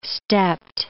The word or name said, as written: stepped